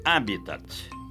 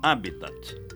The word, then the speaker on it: habitat: Pronuncie corretamente. Habitat, como proparoxítono, sem esquecer o T mudo final. Habitat.